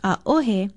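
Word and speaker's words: a-ohe (ʻaʻohe): E, Okina, O-H-E, and pronounced a-ohe, means no too, but it is generally used to mean none.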